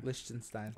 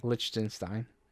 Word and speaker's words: Liechtenstein: The Czech Republic, Belgium, Israel, Egypt, Malaysia, Liechtenstein, Am I saying that right? – Liechtenstein. – Liechtenstein.